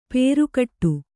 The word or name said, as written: ಪೇರುಕಟ್ಟು